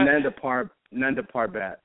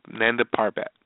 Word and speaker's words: Nanda Parbat: Nanda Parbat. You had it right. – Parbat. Okay, just the way it's – okay, Nanda Parbat.